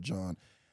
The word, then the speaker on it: Jean: And then the Jean, people pronounce it Jean or John.